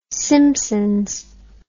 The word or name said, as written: simpsons